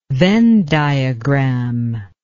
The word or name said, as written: Venn diagram